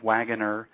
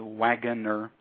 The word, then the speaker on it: Wagoner: But the title is Lucid Dreaming, Gateway to the Inner Self by Robert Wagoner. And I'm just going to spell my last name because it's spelled a little bit differently. It's W-A-G like George, G like George, O-N-E-R, so Wagoner.